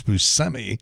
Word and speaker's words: Buscemi: Steve Buscemi, and apparently we were saying it wrong all those years. People were saying Buscemi, and it was never Buscemi, it's Buscemi, the correct pronunciation, 64.